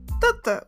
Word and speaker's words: Tutta: Tutta, which sounds like tether. I or uh, tether.